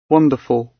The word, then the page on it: wonderful: British English